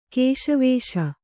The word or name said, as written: ಕೇಶವೇಶ